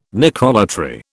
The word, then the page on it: necrolatry: British English